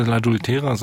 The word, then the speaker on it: L'Adultera: Ich würde L'adultera sagen. Viele sagen L'adultera, aber aus dem Italienischen würde man L'adultera sagen.